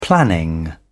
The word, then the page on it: planning: British English